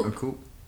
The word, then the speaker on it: coup: What is it? Coup? She planned a coup. – A coup. – A coup. – The P is silent.